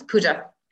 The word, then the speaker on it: Puja: Yes, Puja.